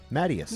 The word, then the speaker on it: Matyas: Matyas.